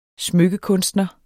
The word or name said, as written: smykkekunstner